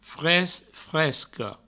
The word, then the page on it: fresc, fresca: fres, fresco